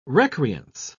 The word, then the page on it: recreance: British English